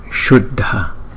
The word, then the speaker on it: Shuddha: Còn chữ S có dấu sắc trên đầu chúng ta đọc là Shuddha.